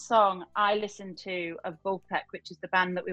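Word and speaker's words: Vulfpeck: Vulpec. – Vulpec. – Yeah, it's V-U-L-F-P-E-C-K, Vulpec.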